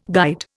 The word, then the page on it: guide: Indian